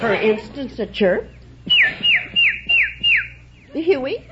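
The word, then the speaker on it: Hewie: No, it's H-E-W-I-E. It's the syllable that I say. Huey. You let your tongue be loose inside and just say Huey.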